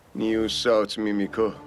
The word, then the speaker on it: نیو ساوت میمیکو: همین کشور نیو سامیمیکو. – نیو ساوت میمیکو تلفظش هست. نیو سامیمیکو ته تلفظ نمیشه.